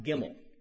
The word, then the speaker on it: Gimel: The word, the letter, is pronounced Gimel.